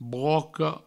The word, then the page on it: broca: broco